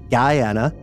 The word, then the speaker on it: Guyana: Guyana is pronounced like Diana. Essequibo is pronounced with the Q-U-I like in quit.